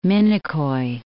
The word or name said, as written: Minicoy